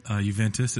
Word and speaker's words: Juventus: Ronaldo to Juventus. Did I say that right? – Juventus, it's fine.